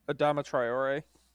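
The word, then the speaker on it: Traoré: Tiore, Adama Tiore went, how do you pronounce it? Tiore? – Adama Traore. – Traore, sorry.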